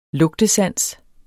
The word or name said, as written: lugtesans